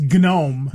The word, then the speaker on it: GNOME: And they pronounce the G of GNU, GNU. – Mm-hmm. – And so it's GNOME.